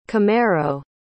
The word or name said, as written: camero